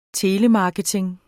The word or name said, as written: telemarketing